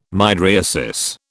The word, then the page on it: mydriasis: British English